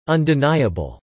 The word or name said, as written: undeniable